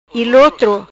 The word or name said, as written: Ilotro